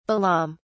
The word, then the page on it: balaam: British English